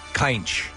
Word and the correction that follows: Cynch: It's Cinch.